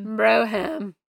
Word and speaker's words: Brougham: If you know the correct pronunciation of that word, it's Brougham. Hit us up. – Brougham.